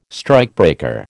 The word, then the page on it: strikebreaker: American English